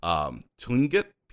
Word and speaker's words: Tlingit: that's found in the folklore of the, I'm going to see if I can pronounce, it has a T and an L. Tlingit people of the Pacific Northwest coast of North America.